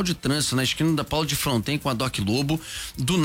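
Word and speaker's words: Paulo de Frontin: Só para registrar, os moradores locais e das antigas costumam pronunciar Paulo de Fronten mesmo, apesar de escrever Frontin.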